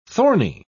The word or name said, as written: thorny